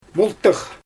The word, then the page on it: myltyx: Xyzyl